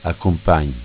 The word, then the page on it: Accumpagn: Dialetto